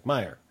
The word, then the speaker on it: Meyer: How do you say her name? Meyer. – Go away, Stephanie. That's how you say it.